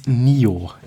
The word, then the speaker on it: Nioh: Genauer gesagt ist es hier die PlayStation 4, ein Action-Rollenspiel namens Nioh. Ich hoffe, ich habe das Richtige ausgesprochen. – Hast du, ja.